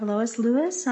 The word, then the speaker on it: Lois Lewis: Next up, we have Lois Lewis on remote.